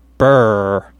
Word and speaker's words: brr: Uh, a uh, expression spelled BRR, which we would pronounce as burr.